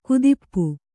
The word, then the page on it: ಕುದಿಪ್ಪು: kudippu; kannada